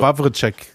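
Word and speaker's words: Wawrczeck: ähm, ja, wie spricht man so einen Namen aus mit vielen Ws und wenigen Vokalen? Ich glaube, also. Wawritschek, Wawritschek, irgendwie so, ist, ist schon richtig.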